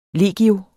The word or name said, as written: legio